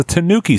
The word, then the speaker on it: tanuki: It's a tanuki suit. – The tanuki suit. Okay. – I'm so mad. – Be mad. Use it. – I'm seething with curiosity. – I do like the tanuki leaf.